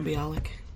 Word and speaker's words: Bialik: I think Maya Bialik.